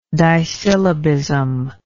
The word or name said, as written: disyllabism